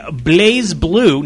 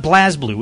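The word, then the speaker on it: BlazBlue: So we're going to be playing Blaze Blue, not Blaz Blue. We kept calling it Blaz Blue last week because that's exactly what it looks like. It's B-L-A-Z-B-L-U-E.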